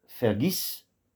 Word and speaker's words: Vergiss: Le V en début de mot se prononce comme un F, comme dans Fantaisie, dans Falbala. Bon, ici on a Vergiss, vers numéro 2, Vergiss nicht.